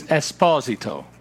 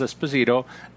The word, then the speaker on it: Esposito: It's not. It's Esposito, and that's the correct pronunciation in the Italian community, but it has come to be known as Esposito,